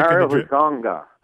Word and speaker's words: Hezonja: Mario Hezonja. – Hezonja.